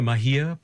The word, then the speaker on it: Mahia: the Mahia Peninsula? – That's correct.